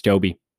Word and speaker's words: Tobe: And now I was today years old when I found out it's actually pronounced Tobe Hooper and not Tobe. I had thought Tobe was a weird name, but spelled T-O-B-E.